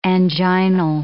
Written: anginal

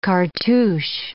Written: cartouche

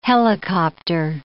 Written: helicopter

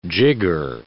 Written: jigger

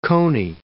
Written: coney

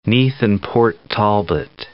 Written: Neath and Port Talbot

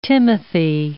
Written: timothy

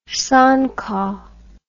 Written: songkok

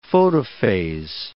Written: photophase

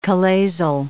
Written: chalazal

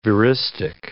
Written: veristic

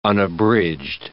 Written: unabridged

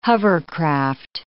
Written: hovercraft

